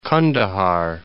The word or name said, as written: Qandahar